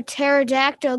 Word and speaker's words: pterodactyl: Hey, Reed, why can't you hear a pterodactyl going to the bathroom? – I don't know. Why? – The P is silent.